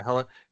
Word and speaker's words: Hela: Hela.